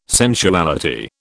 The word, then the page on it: sensuality: British English